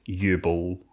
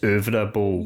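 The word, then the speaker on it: Uwe Boll: So, obviously, over the course, we watched quite a lot of U-Bowl films. – Yes, good old U-Bowl. Yeah. That's not how you say it, though, is it's over a bowl.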